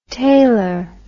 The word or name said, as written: tailer